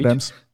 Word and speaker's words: Abrams: Abrams, Abrahams, neviem to ani vysloviť, Abrams.